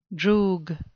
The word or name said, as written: droog